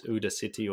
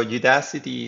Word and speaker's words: Udacity: I never know how to pronounce whether it's Ooda City or Udacity.